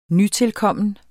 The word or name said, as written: nytilkommen